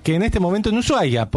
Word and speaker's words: Ushuaia: Igual son creencias, porque viste, a veces de golpe entras, Ushuaia, sí. Puede entrar. Que me encanta que se escriba SH, pero no se pronuncia, la H es muda.